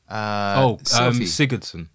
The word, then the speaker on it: Sigurdsson: But we talked about that while I was talking to the boys on the voice notes, because Giveson. – Sigurdsson. – I see. Butchered the man's name there.